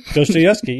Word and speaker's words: Dostoevsky: Dostoevsky, yeah.